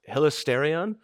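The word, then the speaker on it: hilasterion (ἱλαστήριον): hilasterion.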